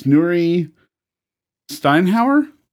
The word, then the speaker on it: Nuri Steinhauer: But joining us right now is Nuri Steinhauer.